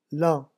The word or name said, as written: lent